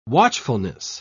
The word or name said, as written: watchfulness